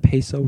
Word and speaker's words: peso: Did you know that peso rhymes with queso? – Queso, peso.